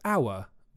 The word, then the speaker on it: hour: With hour, we use an because the H is silent. But with half, we use a because the H is not silent.